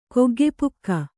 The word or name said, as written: ಕೊಗ್ಗೆಪುಕ್ಕ